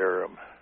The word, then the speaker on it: Urim: and then some of my cross-reference scriptures led back to Exodus and Numbers, and the one in Numbers, it's talking about the Urim. Is that how, yes. Am I saying that right? – Yeah, the Urim and the Thummim, yeah.